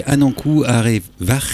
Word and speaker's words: Anankou Arevar: Et le titre s'appelle, alors, c'est Anankou Arevar. – C'est ça, ouais, grave, c'est ça, ouais. – C'est bien prononcé ? – Ouais, bah, en tout cas, phonétiquement, c'est ça.